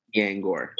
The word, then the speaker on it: Niengor: How do I say your last name? – Nyangor. – There we go.